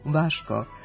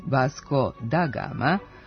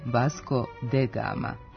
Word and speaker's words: Vasco da Gama: Portugalci kažu Vaško, Brazilci Vasco, a mi smo usvojili transkripciju portugalskog prema brazilskom izgovoru, koja je bliža pismu, tako da se odavno ustalio izgovor koji ne treba menjati, Vasco da Gama, a ne Vasco de Gama.